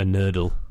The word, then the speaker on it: nurdle: It's called a nurdle. – A nurdle? – A nurdle. It's called, it's spelled N-U-R-D-L-E.